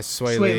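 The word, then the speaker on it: Swae Lee: Yeah, Sway Lee.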